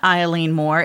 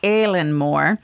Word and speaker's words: Eilean Mòr: According to Paranormal Guide, so I had to look up how to pronounce this because to me, it looks like Eileen, and immediately I launch into like, come on, Eileen. But it's not Eileen Moore, it's Aileen Moore.